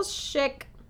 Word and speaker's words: Schick: When Vera was 19, she married a wealthy banker named Carl Schick. Schick. Schick. – Carl, what's how do you spell it? – S-C-H-I-C-K. Schick.